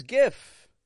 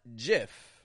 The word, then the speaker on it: GIF: That is GIF, not JIF. A lot of people will say JIF.